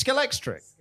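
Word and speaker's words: Scalextric: I went right into Hamleys and I bought the biggest Skeletric. Am I even pronouncing Skeletric? Skeletric. – Skeletrix. – Was it Skelectrix or Skelextric? – No, it's Skelextric. No, I'm saying it wrong.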